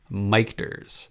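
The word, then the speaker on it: Michter's: that's right the, the pronunciation is technically mike ders uh, but I'm just gonna keep calling it Mictors as I always have, uh, because it's Michael and Peter.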